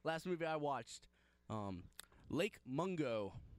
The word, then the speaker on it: Mungo: Mungo. – Yeah, so Mungo is spelled M-U-N-G-O, so okay.